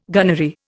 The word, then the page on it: gunnery: Indian